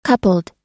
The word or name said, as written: coupled